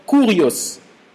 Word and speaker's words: kurios: Le mot seigneur vient d'un mot grec que l'on prononce kurios, K-U-R-I-O-S, kurios.